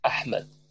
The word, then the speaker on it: Ahmed: This is probably the most difficult pronunciation of all Arabic, because it doesn't, it, like Ahmed, Ahmed. – Yeah, it's Ahmed.